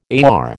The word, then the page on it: AR: American English